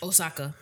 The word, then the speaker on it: Osaka: Osaka. – Yes.